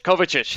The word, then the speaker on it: Kovacic: Because I know once that, I can't even pronounce the name, Kovic, their new midfielder. – Kovacic, there we go.